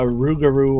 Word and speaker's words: Rougarou: It's said that you can become a Rougarou or Lugaru, depending on your pronunciation, if you break certain religious norms.